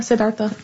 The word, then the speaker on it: Siddhartha: I was thinking about when they talked about Buddhism and they said that when Siddhartha, is that how you say it? – Yeah, Siddhartha.